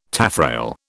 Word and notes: taffrail: British English

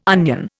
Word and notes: onion: Indian

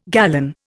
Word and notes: gallon: Indian

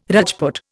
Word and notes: Rajput: Indian

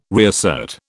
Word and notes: reassert: British English

